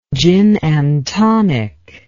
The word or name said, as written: gin and tonic